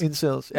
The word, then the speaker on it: Incels: Er det ikke, jeg tror, det er sådan udtalt. – Incels. – Incels, ja.